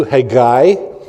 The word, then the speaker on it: Haggai: It is actually pronounced, if you want the line on it in Hebrew, it's Haggai. The last syllable is emphasized. It's not three syllables, it's two, Haggai.